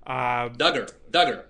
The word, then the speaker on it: Duggar: if I can segue a bit, we had this episode a few days ago where Josh Duggar, if I'm pronouncing the name correctly, Duggar.